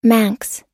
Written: Manx

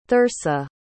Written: thersa

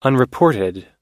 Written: unreported

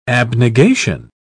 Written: abnegation

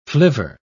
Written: flivver

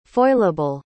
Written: foilable

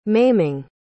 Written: maiming

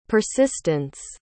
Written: persistance

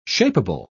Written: shapable

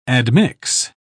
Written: admix

